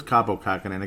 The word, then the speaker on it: Kaapo Kähkönen: I believe it's Kaapo Kähkönen.